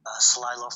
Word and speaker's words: Celilo: So, you brought with you your poem, Celilo Falls Inundation. Did I say that right? – Celilo Falls Inundation, yeah.